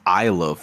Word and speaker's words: Isla: Isla, like it's Isla, fool. Like the S is silent.